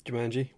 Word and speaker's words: Jumanji: Uh, I'm assuming the one with the animals was Jumanji. Yep, Jumanji. Jumanji. What did I say? No, um, you said it correctly.